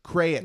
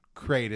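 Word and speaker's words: krayt: I feel like it should be two syllables. So like cray, but then a hard T. Crayit. Mm. You know, that's how I want to say it. But I think we both agreed crate is what we're going to say.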